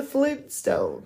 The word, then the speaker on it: Flintstones: We've been pronouncing it without that first T, so this is really awkward now. No one says the Flintstones. – Flintstones. – It's the Flintstones.